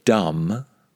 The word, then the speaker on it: dumb: The word dumb, spelt D-U-M-B, with a silent B on the end, exactly the same pronunciation as thumb on my hand.